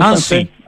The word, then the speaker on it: Annecy: Annecy, en Annecy.